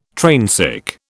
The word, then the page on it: trainsick: British English